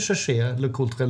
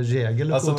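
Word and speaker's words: Jaeger-LeCoultre: Heter, säger ni Cherché Le Coultre eller Jäger Le Coultre? Alltså, man känner sig lite bajsnödig om man säger Cherché Le Coultre. – Exakt! – Men, ja. men, alltså- men, men, det är ju så det uttalas Exakt, egentligen.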